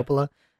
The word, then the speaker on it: Coppola: Francis Ford Coppola. I'm pronouncing that right? – Yeah. – Coppola.